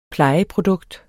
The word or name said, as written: plejeprodukt